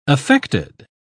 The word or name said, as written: affected